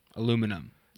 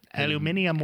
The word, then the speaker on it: aluminium: That's how they say it in England. – Oh, yeah? – Tilapia wing. Aluminum. – Aluminium wing.